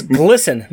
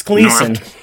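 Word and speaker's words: Glisan: No, it's Glisten. No, it's Gleason.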